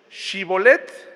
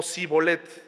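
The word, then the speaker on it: Shibboleth: Shibolet o Sibolet. Como aquellas personas de esa región no lo podían pronunciar, de esa forma sabían de qué región eran y pues los mataban.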